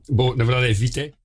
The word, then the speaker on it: Vitae: Mas na verdade é Vitae.